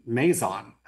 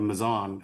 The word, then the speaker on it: Mazon: So for as long as I can remember, when I was taught about these fossils back in college, everyone pronounced it Mazon instead of Mizan. And it was actually just recently that Danita Brandt, faculty member at, I believe, Michigan State, for most of her career, she was from the Mizan area, and she published some work and provided several presentations at regional and national meetings to tell everybody that Mizan was actually the correct pronunciation.